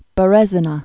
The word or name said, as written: Berezina